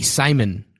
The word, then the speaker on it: Scymon: Then we also have Mickey Simon, which is spelled S-C-Y-M-O-N, possibly the coolest spelling of Simon ever to be created.